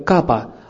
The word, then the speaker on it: капха: Х не читается, поэтому правильно произносится как капа.